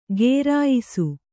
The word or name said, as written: ಘೇರಾಯಿಸು